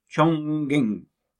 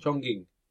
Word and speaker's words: Chongqing: This is again in China, a place called Chongqing in South China. – Nicely done, Dad. – Nice pronunciation. – Chongqing. – Yeah, Chongqing.